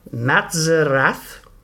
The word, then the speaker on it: Matzerath: Oscar Matazaf. I've had such the hardest time learning to pronounce that name. Oscar Matzerath. There we go.